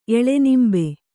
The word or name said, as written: ಎಳೆನಿಂಬೆ